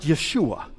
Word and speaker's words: Yeshua: And if you go back to the original Hebrew text and you look at the name that is translated into English, Jesus, it is the name Joshua, or better pronounced, Yeshua.